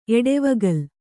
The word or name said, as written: ಎಡವೆಗಲ್